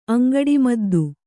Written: ಅಂಗಡಿಮದ್ದು

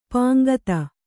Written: ಪಾಂಕ್ತ

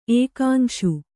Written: ಏಕಾಂಶು

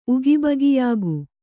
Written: ಉಗಿಬಗಿಯಾಗು

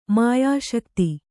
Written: ಮಾಯಾಶಕ್ತಿ

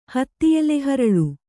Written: ಹತ್ತಿಎಲೆಹರಳು

